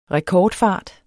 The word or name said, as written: rekordfart